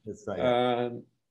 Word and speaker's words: dystonia: Can medicinal cannabis be used for torsion dystopia? – Dystonia. – Yeah, you're correct. You've got the pronunciation correct.